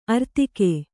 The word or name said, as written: ಆರ್ಥಿಕ